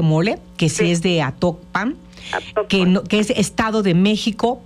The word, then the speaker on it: Atocpan: No, solamente me acuerdo que me llamó la atención eso, que ellos pronunciaron Atocpan y yo conozco un Actopan en Hidalgo. – Uh-huh. Este es Atocpan. – Ajá, sí, San Pedro Atocpan.